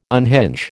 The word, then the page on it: unhinge: American English